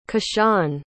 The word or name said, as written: keshaun